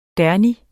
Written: derny

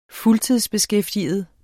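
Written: fuldtidsbeskæftiget

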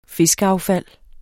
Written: fiskeaffald

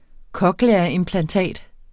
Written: cochleaimplantat

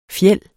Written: FJELDE

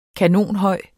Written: kanonhøj

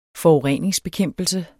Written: forureningsbekæmpelse

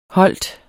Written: holdt

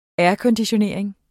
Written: aircondition

